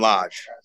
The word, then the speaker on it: Lage: And you work quite a bit with Julian, and I'm not sure how to pronounce his last name, L-A-G-E. – Julian Lage. – Lage. Okay. I wanted to get it right, or at least not get it right.